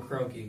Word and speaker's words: Kroenke: So it's Stan Kroenke, because he wants to... – Is that actually how you pronounce his name? – Yep.